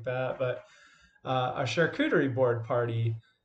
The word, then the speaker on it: charcuterie: So when we talk about charcuterie, that's how you pronounce it. It's a French term,